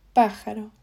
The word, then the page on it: pajaro: Spain Spanish